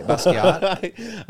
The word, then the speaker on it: Basquiat: Is that how you pronounce it? Okay. Basquiat.